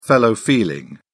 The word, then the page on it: fellow feeling: British English